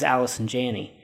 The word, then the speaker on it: Allison Janney: The thing about, so then when I watched the movie, I didn't know, oh, this is Allison Janney.